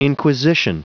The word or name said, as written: inquisition